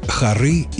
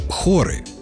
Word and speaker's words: хоры: Есть в русском языке такие интересные слова, как «хоры» и «хоры». Пишутся одинаково, ударения имеют разное, и понятия обозначают различные.